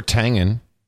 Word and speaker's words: Tangen: Or Tangen. – No, Tangen, yeah, because it's pronounced like the breakfast drink, he says. So it'd be Tangen, George Tangen.